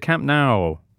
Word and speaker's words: Camp Nou: Instead, he was off at the Camp Nou, which rhymes with cow.